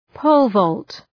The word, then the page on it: pole-vault: ‘pəʋlvɔ:lt